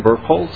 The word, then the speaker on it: Birkholz: My name is Jim Birkholz. That's spelled B-I-R-K-H-O-L-Z.